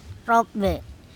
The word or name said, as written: TOpbe